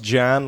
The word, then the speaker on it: Jan: It's Jan, pronounced Jan, like pronounce, jam, it's Jan, but with an N.